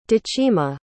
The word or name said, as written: decima